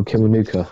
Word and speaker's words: Kiwanuka: Michael Kiwanuka.